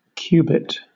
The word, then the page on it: cubit: British English